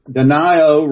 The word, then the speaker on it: Denio: Denio rhymes with Ohio. – Okay? I know, but the Italiano way is Denio.